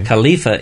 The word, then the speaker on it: Khalifa: Khalifa is the Arabic pronunciation.